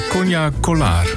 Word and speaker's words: Konya Kolar: Mevrouw Konya Kolar, toch? – Ja. – Zeg ik het goed? – Ja, heel goed.